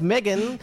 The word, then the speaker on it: Meghan: Meghan Markle is pronounced Megan.